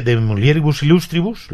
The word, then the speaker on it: De Mulieribus Illustribus: Escribe De Mulieribus Illustribus, lo he dicho bien, ¿no?